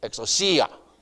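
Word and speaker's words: exousia (ἐξουσία): X, now I'm going to say it in the syllables. X, U, C, A. You got it? Four syllables. And the emphasis in the pronunciation on the C. So, exousia. Exousia.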